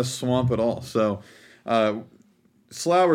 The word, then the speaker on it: Slough: So, Americans apparently say it, Slough. The Slough of Despond, where the Brits say the Slough of Despond, which is what I've been calling it.